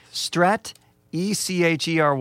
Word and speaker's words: Stratechery: I just realized I have no idea how to pronounce this, but Strat, E-C-H-E-R-Y. – Well, I think it's strategy and technology combined. So Stratechery. Stratechery. Oh, well, that couldn't be more clear.